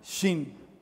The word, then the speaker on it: Shin: Es la letra Shin, Shin, S-H-I-N. Eso no existe en nuestro alfabeto porque nosotros no pronunciamos la SH como griegos tampoco.